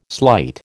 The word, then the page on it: slight: American English